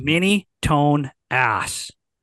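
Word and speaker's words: Minitonas: Minitonus, I believe is how you say it. – Minitonus. – It's like mini-tone ass.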